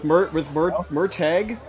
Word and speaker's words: Murtagh: With Murtagh, it's Mur rhymes with purr.